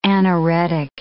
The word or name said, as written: anoretic